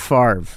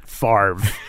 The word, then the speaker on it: Favre: be like, what do you mean Favre? – What is it? – Favre. But like it's like the R doesn't even come before the V. It's like you've actually your pronunciation is reordering the letters yeah.